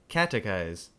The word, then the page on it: catechize: American English